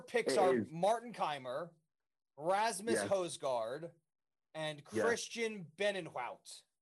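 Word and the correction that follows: Bezuidenhout: Is that how you say it? – Bezidenhout. – Bezidenhout. – Bushel of wheat. – I'll get it right eventually. Look, I'm trying, okay? At least I'm trying. How do you say it? – Working on it. Bezidenhout.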